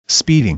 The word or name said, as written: speeding